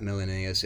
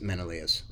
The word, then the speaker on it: Menelaus: Menelaus, by the way, I was saying his name wrong. It's not Menelaus, it's Menelaus.